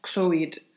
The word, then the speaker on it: Clwyd: Valley of Clwyd.